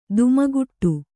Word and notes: ಧಿಮ್ಮಿಕಿಟ: dhimmi kiṭa

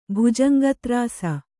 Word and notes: ಭುಜಂಗತ್ರಾಸ: bhujanga trāsa

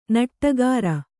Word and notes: ನುಡಿಗಾರ: nuḍigāra

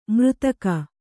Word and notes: ಮರೆದೆಗೆ: kannada

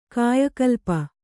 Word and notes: ಕಾಯಕಲ್ಪ: kāyakalpa

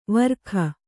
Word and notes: ವಾರಕ: kannada

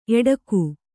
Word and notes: ಏಡೂಕ: ēḍūka

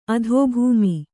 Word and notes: ಅಧಿಭೌಮ: adhibhauma

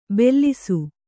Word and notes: ಬೆಲ್ಲಿಸು: bellisu

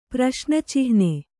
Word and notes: ಪ್ರಶ್ನಚಿಹ್ನೆ: praśna cihne

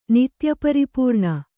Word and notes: ನಿತ್ಯಪರಿಪೂರ್ಣ: nitya paripūrṇa

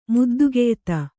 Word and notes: ಮುದ್ದುಗೆಯ್ತ: muddugeyta; kannada

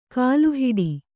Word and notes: ಕಲ್ಲುಹೆಟ್ಟೆ: kalluheṭṭe

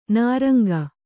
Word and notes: ನಿರಂಕ: niraŋka

